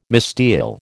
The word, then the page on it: misdial: American English